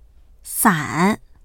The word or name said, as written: san3